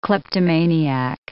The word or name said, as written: kleptomaniac